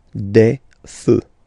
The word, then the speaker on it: feux: You don't put s, but you will put x instead, and then you don't pronounce it. Des feux.